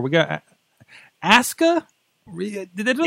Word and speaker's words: Asuka: Japanese pronunciation. WWE's doing one thing right because Japanese pronunciation, you never pronounce the, pronounce the U. – Okay, mm. but it's Asuka.